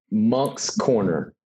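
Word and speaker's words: Moncks Corner: Monk's Corner. – Monks with the priest? – It's pronounced like that, but there's a C in between the N and the K.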